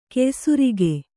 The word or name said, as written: ಕೆಯ್ಸುರಿಗೆ